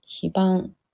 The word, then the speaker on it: 非番: その切り替わりがね、うん、非番。非番って読むんですね。ね、非番と読むのか。